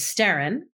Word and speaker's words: Sterrin: Sterrin, Sbtrrim, is the Gaelic word for storm. And the Western pronunciation of Sterrin is Sterrin.